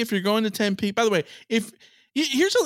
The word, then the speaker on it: Tempe: Then you have to pronounce the name of the city correctly. It's Tempe. Tempe, not Tempe.